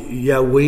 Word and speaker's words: Yahweh: Le nom, l'Éternel, le nom sacré du tétragramme, là, qu'on appelle il prononce Yahweh, il y en a d'autres qui le prononcent Jéhovah.